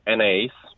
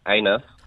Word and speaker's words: Anaïs: So when my dad met my partner, who is French and her name is Anaïs, but he pronounced her name Anus.